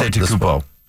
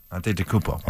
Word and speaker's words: Antetokounmpo: How do you say his name? Go ahead. I'm putting you on. – Antetokounmpo. – Antetokounmpo? – Antetokounmpo. – Antetokounmpo. Giannis Antetokounmpo.